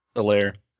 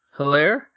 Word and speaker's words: Helaire: Helaire. Helaire.